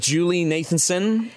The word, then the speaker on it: Julie Nathanson: We'll start with Julie Nathanson.